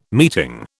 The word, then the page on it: meeting: British English